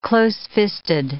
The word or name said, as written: closefisted